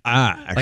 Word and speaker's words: Ash: Because it's spelled A-S-H, right? – So Ash?